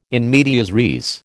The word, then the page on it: in medias res: American English